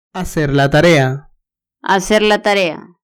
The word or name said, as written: hacer la tarea